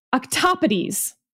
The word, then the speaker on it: octopodes: But this person pointed out that if you really wanted to do it correctly, you would do plural in Greek, which looks like the word octopode, but is actually pronounced, wait, I'm going to get it wrong. Octopodes.